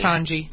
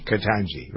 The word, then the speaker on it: Ketanji: Is it Ketanji or Ketanji Brown Jackson? – Ketanji. – Ketanji, right.